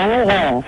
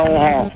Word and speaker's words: Oron: À Oron. – Ça s'écrit comment? – Oran. – Non, pas Oran. Oron.